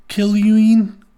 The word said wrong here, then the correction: Keuilian: Badrus is Bedros. And then his last name is Keuilian.